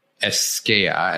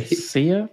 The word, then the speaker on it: Escea: Escea, and I think that's how to pronounce it. E-S-C-E-A. – Escea? Yeah, I'm not sure.